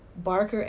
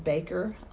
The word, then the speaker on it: Barker: And as we are, our last name is Barker, and we get called Baker a lot. Gary's name is spelled G-E-R-R-Y.